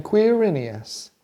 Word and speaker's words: Quirinius: Sure you can. Quirinius. – Quirinius.